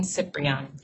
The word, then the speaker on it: Ciprian: My full name is Kimberly Ann Ciprian. It's spelled C-I-P-R-I-A-N.